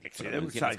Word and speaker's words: Exide: Se depende como esto, se acuerda que ven unas baterías Exide o Exide, Exide. – Como quiera, Hormuz u Hormuz, dentro... – Se escribe Exide, se pronuncia Exide, decía el comercial de las baterías.